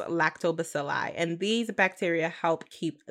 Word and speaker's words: Lactobacilli: Lactobacilli. – That's what Sam said. That's what it is. – Let me double check on this nigga Google real quick, though. – Lactobacilli.